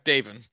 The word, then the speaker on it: Davon: Okay, Davin.